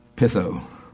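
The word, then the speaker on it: peithō: You spell it P-E-I-T-H-O, pitho.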